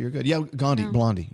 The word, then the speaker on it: Blondie: And by the way, I realize Blondie rhymes with Gandhi in case anyone texts in.